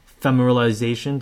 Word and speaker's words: ephemeralization: We talked about ephemeralization.